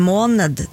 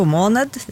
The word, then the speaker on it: måned: Men selve ordet måned da, det kan uttales på mange forskjellige måter, så du har jo måned, det er sikkert noen som sier det, og det kommer da egentlig fra gammelnorsk maunader eller noe sånt. Min gammelnorske uttale er ikke nødvendigvis helt korrekt, men tilnærmet der. Ja. Også er det en del dialekter hvor man, eller en del, en uttalemåte er at man utelater den siste D-en og bare sier måne. Da blir det akkurat samme ord som denne gule osten, sant? – Mm.